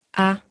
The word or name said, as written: a